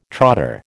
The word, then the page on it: trotter: American English